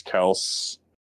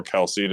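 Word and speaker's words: Kelce: Other than Yo Zach, did you see when uh Travis Kels or Kelsey, depending on how you say it, but I think his family says Kels. I believe it's Kelsey. Yeah, but I think his family says Kels. Like I think he himself says Kels, and we've all just been saying Kelsey is what I understand.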